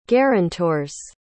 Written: guarantors

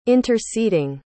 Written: interceding

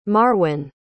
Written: marwan

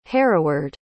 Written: hereward

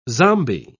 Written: zombie

